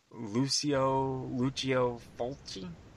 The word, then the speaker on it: Lucio Fulci: Lucio Fulci? – Yeah.